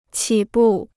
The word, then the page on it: 起步: qǐ bù